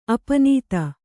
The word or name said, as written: ಅಪ್ಪುನಿಧಿ